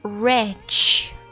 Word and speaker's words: wretch: 第一个是 WRETCH， Rich。